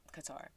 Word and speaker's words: Qatar: We were learning about Qatar, and she was telling us how it's Qatar. It's pronounced Qatar.